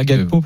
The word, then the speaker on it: Gakpo: On dit effectivement Cody Gakpo, même si son nom n'est pas d'origine. – Bon, chacun dit comme il veut. On sait moi les prononciations. – Moi, je vais dire Gakpo parce que c'est important.